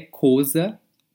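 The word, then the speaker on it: Xhosa: A Amanda, ela é coza, que se escreve com X, é, é X-H-O-S-A.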